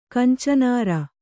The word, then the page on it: ಕಂಚನಾರ: Kannada